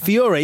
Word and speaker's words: Feore: Colm, I don't know how to pronounce his Feore. Feore, is that right? Yeah. – yeah.